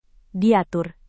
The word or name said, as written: diatur